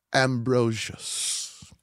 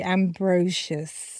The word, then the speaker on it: Ambrosius: One more time for me, Ambrosius. Is that how you said it? – Ambrosius. – Okay, so some people do say Ambrosius, right? – Okay. – Then it's Ambrosius.